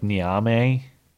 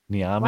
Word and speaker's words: Niamey: Here's how you pronounce it. Oh, dang it. It doesn't have the audio clip. It's like Niamey. Niamey.